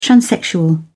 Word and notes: transsexual: British English

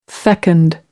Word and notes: fecund: American English

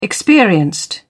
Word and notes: experienced: British English

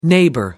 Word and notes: neighbour: American English

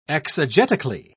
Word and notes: exegetically: British English

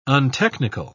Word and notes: untechnical: British English

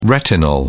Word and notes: retinol: British English

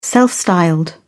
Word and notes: self-styled: British English

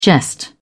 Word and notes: jest: British English

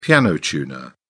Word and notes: piano tuner: British English

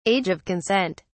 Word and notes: age of consent: British English

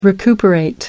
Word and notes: recuperate: American English

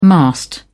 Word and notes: mast: British English

